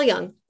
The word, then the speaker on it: Jung: I think, so Freud definitely, Freud met Jung, right? The J is pronounced as a Y. – Mm-hmm. Carl Jung.